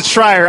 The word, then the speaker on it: Schreier: because they know to pronounce it Schreier. Otherwise, it does look like Schreier.